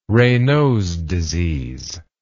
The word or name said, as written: Raynaud's disease